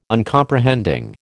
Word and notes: Uncomprehending: American English

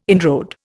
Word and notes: inroad: Indian

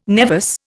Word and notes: nevus: Indian

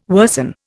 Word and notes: worsen: Indian

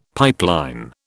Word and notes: pipeline: British English

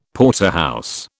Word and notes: Porterhouse: British English

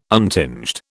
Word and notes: untinged: British English

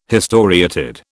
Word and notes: historiated: British English

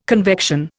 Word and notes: convection: Indian

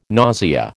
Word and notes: nausea: American English

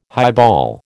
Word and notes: highball: American English

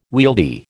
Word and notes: wieldy: American English

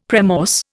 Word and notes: premorse: Indian

